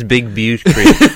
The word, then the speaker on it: Big Butte Creek: There is an actual place called, the correct pronunciation is Big Butte Creek, but we prefer to call it Big Butt Creek.